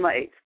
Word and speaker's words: Maat: And you will learn the truth of a lot of things, including the fact that there are many words that we've been told that have actually been mispronounced. It's supposed to be Maat.